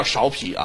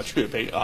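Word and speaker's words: 芍陂: 淮南现在叫安丰堂啊，它过去的名字叫雀碑啊，不叫芍皮啊，古语发音啊叫雀碑啊。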